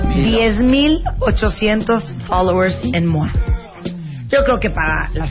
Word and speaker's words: Moi: Pero es muy fácil, es revista MOA, que se escribe M-O-I. – Y acuérdense que cuando la vean, ustedes digan ¡Mua! Y aparte, ahorita buscamos el número de ¡Mua!